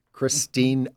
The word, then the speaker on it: Christina: Because, as you say on social media, dear everyone, my first name has an A on the end of it. It has three syllables, the last of which is pronounced like Miss Ritchie, as in Christina Ritchie, Miss Applegate, as in Christina, or Miss Aguilera.